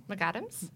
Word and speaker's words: McAdams: McAdams? – McAdams.